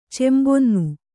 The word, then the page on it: ಚೆಂಬೊನ್ನು: cembonnu